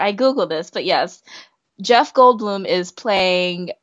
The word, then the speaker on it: Jeff Goldblum: Say his name again because you just butchered it. – I'm so excited that I butchered his name. Jeff Goldblum.